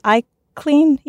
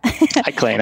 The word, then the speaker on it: ICLN: So, Mike mentioned ICLN, which I guess we can pronounce as iClean, yeah? – iClean, absolutely.